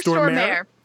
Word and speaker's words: Stormare: Peter Stormare.